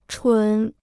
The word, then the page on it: 纯: chún